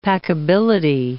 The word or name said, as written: packability